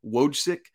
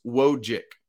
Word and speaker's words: Wojcik: All right, I want to dive in a little more to Paxson Wojcik, which, by the way, I've been pronouncing that C, Wojcik. It's just Wojcik. That's it.